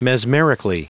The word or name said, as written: mesmerically